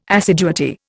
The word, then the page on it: assiduity: Indian